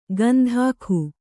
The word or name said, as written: ಗಂಧಾಖು